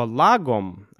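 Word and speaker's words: lagom: Сразу скажу вам про произношение, да, вот это слово «лагом», о котором мы сегодня будем говорить, конечно, это шведское слово, и по-шведски оно произносится как-нибудь типа «лагом», да, «лагом», что-то такое. Но, как бы, я буду говорить по-русски, произносить это слово по-русски, используя правила русской фонетики, поэтому просто лагом.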